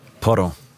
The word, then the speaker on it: Porro: Da kannst du jetzt wieder glänzen mit der Aussprache, nämlich Pedro Porro. – Porro.